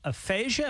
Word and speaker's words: aphasia: People this morning are talking about Bruce Willis having to step away from acting after being diagnosed with aphasia. Am I saying that right? – Mm-hmm.